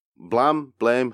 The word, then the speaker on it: Blame: So, yeah, Blame, however you want to pronounce it, because it's spelt Blame, but it's pronounced Blam.